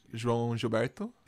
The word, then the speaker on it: João Gilberto: João Gilberto? – Yeah.